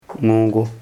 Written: hmõgo

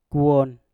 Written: kwon

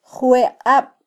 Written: jweqab

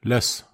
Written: las

